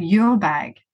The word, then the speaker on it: URBAG: So the idea originally was the combination of URBAN and bag. So I created URBAG this way, and this is the name and the pronunciation I had in my head originally.